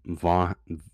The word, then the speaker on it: Vaughan: We can't wait for reviews, and we can't wait for all of you dedicated fans like Adam Vaughn. I'm sorry, I mispronounced that. Adam Vaughan. Adam Vaughan.